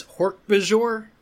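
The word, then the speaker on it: Hork-Bajir: I mean, it is Hork-Bajor.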